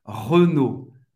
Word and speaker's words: Renault: Renault em francês e Renault, Renault, o E fechado, re, no, a, ou em francês tem o som do O. L e T não vai ser pronunciado. Renault. Duas sílabas.